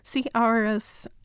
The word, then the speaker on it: C. auris: Is it pronounced auris? – C. auris.